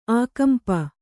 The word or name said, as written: ಆಕಂಪ